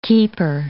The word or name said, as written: keeper